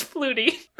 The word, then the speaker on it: Flutie: Dude, like, I'm still missing my man, Flouty. – I thought you were gonna say Angel, but no, it was Flutie. – Oh, Flutie, excuse me. I can never say his name right.